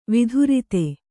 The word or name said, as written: ವಿಧುರಿತ